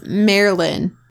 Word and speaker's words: Marilyn: No, you're saying it with too many syllables. – Bottle of champagne. – It's Marilyn. – Marilyn. – Not Marilyn. Mare. – Mare. – Lynn. – Lynn. – No, you did it wrong again.